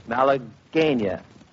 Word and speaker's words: Malagueña: I said malagueña. The announcing staff and the research staff and everybody else seem to be in all kinds of arguments about the pronunciation of Malagueña. But we've decided it's Malagueña,